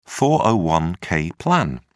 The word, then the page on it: 401-k plan: British English